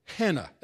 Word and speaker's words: Hannah: Anna, or Hannah, as it would have been pronounced in the Greek world.